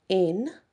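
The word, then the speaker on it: Áine: And the next one is, now it can be pronounced a few ways. It's A-I-N-E. Some people just pronounce it in. Some people pronounce it in there. It just depends. It does have a different sound to it when you talk to many different people. I've talked to so many different people and they do all pronounce it quite differently.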